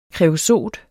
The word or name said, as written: KREOSOT